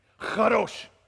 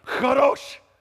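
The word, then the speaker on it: Chadosh (קדוש): Chadosh. Chadosh. All right. That's weak. If the person in front of you is not wiping stuff off the back of their hair, you didn't say it right. Chadosh.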